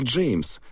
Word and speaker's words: Джейм: Имя произносится как Джеймс, но без «с». Джейм. Настаивает, что именно так и следует его произносить.